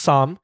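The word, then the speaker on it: sam (쌈): Sometimes you see like someone mispronounced or misspelled sam with an extra s. It's pronounced sam. – So it's S-S-A-M? – Yes.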